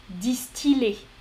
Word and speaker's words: distiller: Parce que, en général, quand on a on va avoir par exemple des mots comme fille, fille, briller, mais distiller. Distiller. Il y a quelques autres exemples. Euh, j'ai pas en tête là. C'est plus rare. C'est plus rare, mais certains mots se prononcent il.